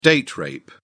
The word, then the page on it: date rape: British English